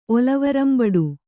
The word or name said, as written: ಒಲವರಂಬಡು